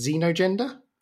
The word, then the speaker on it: xenogender: Xenogender? – Yes.